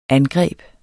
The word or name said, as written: angreb